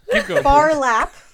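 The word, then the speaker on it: Phar Lap: there is Farlap, and far is spelled P-H-A-R.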